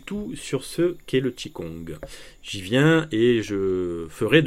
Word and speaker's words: Qigong: Tout d'abord, que veut dire Qigong, qui s'écrit Qi-Q-I et Gong, Kong, G-O-N-G, et se prononce donc Qigong.